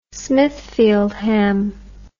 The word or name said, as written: smithfield ham